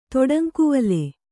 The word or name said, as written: ತೊಡಂಕುವಲೆ